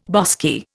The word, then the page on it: bosky: Indian